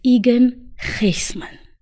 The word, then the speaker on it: Egan Gysmans: And today I have my guest, Egan Gysmans.